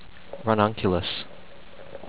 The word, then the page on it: Ranunculus: ran-nun-CUE-lus